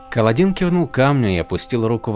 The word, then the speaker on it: Каладин: Он произносил «Каладин» с ударением на последний слог вместо обычного «Каладин» с ударением на первую «А».